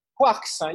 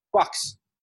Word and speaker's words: Quarks: Quarks, yes, exactly. Quarks. Quarks. French way.